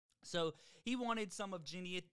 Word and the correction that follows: Genetrius: Genit, why can't I say her name? Genetrius.